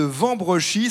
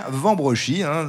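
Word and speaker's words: Wambrechies: Nous sommes toujours dans cette ville de Vambrechy. Ça s'écrit avec un W au début, mais on dit bien Vambrechy. Souvent, on prononce W au début, surtout dans la région. Mais ici, c'est Vambrechy, à 7 km au nord de Lille.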